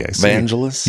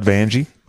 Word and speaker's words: Vangelis: Vangelis? – Yes. – Rhymes with Los Angeles. – Vangelis, not Vangelis, not Vangie.